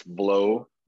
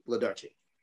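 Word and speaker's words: Włodarczyk: The W is a V. The rest of it is just like it sounds. It's Vlodarczyk. – Vlodarczyk. – Yes, like VL, Vlodarczyk. Yes. – Vlodarczyk, yeah.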